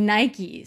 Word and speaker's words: Nike: Und ich glaube, Nike ist ja wirklich durch, dass Nike Nike ist. – Aber ja, sie hat gesagt, ja, hey Leute, ähm, ich habe es immer Nike ausgesprochen, aber das stimmt wohl nicht. Es heißt Nike.